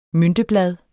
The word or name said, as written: mynteblad